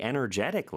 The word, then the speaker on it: energetic: 이 경우도 역시 energy라는 단어를 알면은 또 파생돼서 기억할 수 있는데, 근데 조금 다른 거는 발음이 강세가 달라져요. energy, 이름절인데 energetic, 삼음절의 강세가 있다는 것도 기억해 주세요.